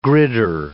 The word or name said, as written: gridder